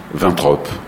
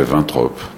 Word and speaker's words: Waintrop: On, devrait dire Vintrop si on était vraiment logique et, on, comme l'est pas jamais en France, donc ça fait Vintrop.